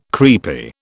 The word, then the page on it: creepy: British English